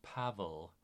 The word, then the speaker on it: Paweł: We would say his first name is Paweł. It's spelled P-A-V-E-L, but in Polish, Paweł Palakowski.